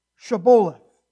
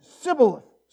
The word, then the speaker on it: Shibboleth: If he said, nay, then say unto him, now say Shibboleth. And he said, Shibboleth. For he could not pronounce it right. I can't pronounce it right either. Sibboleth.